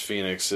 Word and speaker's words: Fenyx: Immortals, Phoenix Rising from Great Beginnings trade paperback because it's spelled F-E-N-Y-X. – It's Phoenix. It's just a weird spelling.